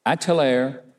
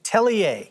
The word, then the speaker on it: Atelier: And founder of the Atelier. Is that right? How you say it? – Atelier.